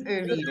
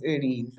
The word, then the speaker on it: Earline: It's Earline. It spells Earline, but it's pronounced Earline.